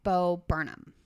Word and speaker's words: Bo Burnham: Okay, my number two is Seth MacFarlane, Bill Burr, Bo Burnham.